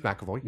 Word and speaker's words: McAvoy: The James McAvoy, I'm saying it wrong again, aren't I? – No, James McAvoy.